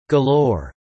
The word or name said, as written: galore